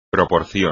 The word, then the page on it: proporción: espagnol